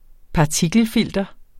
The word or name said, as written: partikelfilter